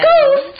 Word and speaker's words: ghost: So our word power word is ghost, which is spelled G-H-O-S-T, ghost. And it's a noun. Now, you have to be careful. We don't say that H. That H in the word is quiet.